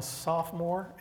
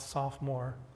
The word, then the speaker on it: sophomore: And in my dream, at the time I'm a sophomore in high school. Not sophomore, soph-o-more. Three syllables in sophomore.